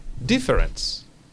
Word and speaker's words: difference: Pero pronunciado, de nuevo, pasamos la pronunciación una o dos sílabas hacia adelante. En castellano decimos diferencia. En inglés vamos a decir difference. Difference. Y terminamos como con una S.